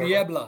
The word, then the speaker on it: Niebla: I don't know what Bob Melvin's history is with Ruben Niebla, but Niebla, did I pronounce that Niebla. – Right? Niebla.